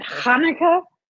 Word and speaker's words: Hanukkah: You need to spit on your microphone. Hanukkah.